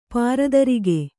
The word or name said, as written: ಪಾರದರಿಗೆ